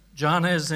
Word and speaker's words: Jannes: Do we pronounce the J's or we not pronounce the J's? Is this Spanish or not? We're pronouncing it? Right. Jannes and Jambres withstood Moses.